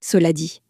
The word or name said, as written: cela dit